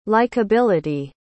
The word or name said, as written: likability